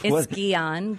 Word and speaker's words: Guillain-Barré: It's Guillain-Barré. – Guillain-Barré syndrome.